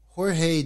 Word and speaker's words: Jorge: Instagram page is in complete Spanish, so I think it's Jorge. Jorge. Yeah. Excuse me. So Jorge Jimenez, his art is beautiful.